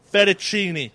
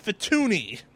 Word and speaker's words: Fettuccine: Fettuccine. Thank you. I thought it was pronounced Fettuni. Like Fettuni Alfredo.